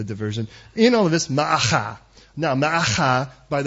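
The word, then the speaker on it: Maacah: Try that, Ma'acha. Because you want to pronounce every letter.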